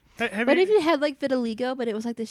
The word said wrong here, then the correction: vitiligo: I like how she mispronounced it. – Vitiligo? – Vitiligo. – Okay, I got it on the second try.